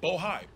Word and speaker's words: Bohai: Bohai. I want to make sure I pronounce it right. – Bohai, B O H A I.